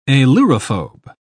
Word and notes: ailurophobe: British English

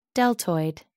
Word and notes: deltoid: American English

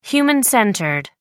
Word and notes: human-centered: American English

